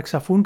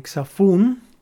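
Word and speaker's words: xaphoon: Lo podéis ver en YouTube, si queréis buscar la palabra xafón, que se escribe X-A-P-H-O-O-N. X-A-P-H-O-O-N, xafón,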